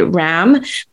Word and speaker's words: Ram: You know, it's written R-A-M, and it's you might say Ram, but it's pronounced Ram. – Okay. – R-A-H-M.